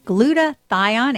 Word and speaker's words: glutathione: Today we're going to be talking about something called, hopefully I can pronounce it right, glutathione.